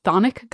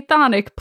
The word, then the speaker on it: Chthonic: In talking about Hades and the Greek underworld, we're going to be talking a lot about the Thonic gods, which is spelled Chthonic. The British pronounce the CH, the Americans don't. So I'm going to say Thonic.